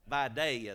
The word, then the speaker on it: Vidalia: A Vidalia onion. Vidalia, the L is silent, it's a Y.